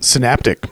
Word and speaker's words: synaptic: Synaptic.